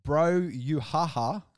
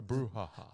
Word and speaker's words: brouhaha: So yeah, anyone that wants to go get a bro-you-ha-ha. – A brouhaha. – That's not how you say it. – Brouhaha. – Well, yeah, brouhaha.